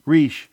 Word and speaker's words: Reich: Reich, spelled R-E-I-C-H, but pronounced Reich, maybe so as not to be confused with the former labor secretary.